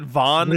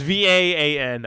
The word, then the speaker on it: Vaan: His name is Van. What? I always pronounced it Vaughn. Zidane is from Final Fantasy 9. It's Vaan.